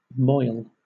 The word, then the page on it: moil: British English